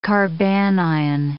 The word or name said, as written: carbanion